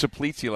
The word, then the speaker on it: Czaplicki: Eddie Shaplitzi. Shaplitzi. – Guys, that's spelled I had some delicious shaplitzi last week at the Greek restaurant. – That's spelled C-Z-A-P-L-I-C-K-I. – Yeah. Shaplitzi.